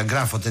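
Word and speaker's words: Graphot: E questa è la pronuncia giusta, Graffot editrice? – Va bene così, Graffot.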